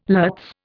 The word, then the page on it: lutz: Indian